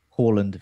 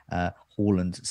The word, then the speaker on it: Haaland: Apparently, Haaland says Haaland is fine. but then a Norwegian has come into the chat saying it's in fact Haaland.